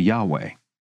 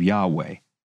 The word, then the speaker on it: Yahweh: Footnote: the Lord is the standard translation of Yahweh, the most likely pronunciation of the Hebrew YHVH.